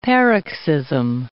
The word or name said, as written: paroxysm